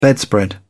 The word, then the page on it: bedspread: British English